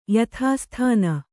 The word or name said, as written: ಯಥಾಸ್ಥಾನ